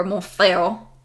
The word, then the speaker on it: Montfort: In 1802, he was called Pierre-Denis de Montfort, or Montfort, or whatever it is in French, because they don't pronounce the end of the T.